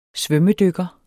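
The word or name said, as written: SVØMMEDYKKERE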